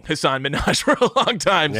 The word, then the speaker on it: Hasan Minhaj: Hasan Minhaj? – Hasan Minhaj is his name.